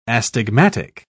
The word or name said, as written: astigmatic